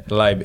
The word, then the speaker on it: Libe: Libe, comment on le prononce, là? – Libe, okay, L-I-B-E. Libe. Ouais.